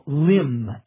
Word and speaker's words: limb: The general term would be an artificial limb, L-I-M-B. Notice the B is not pronounced.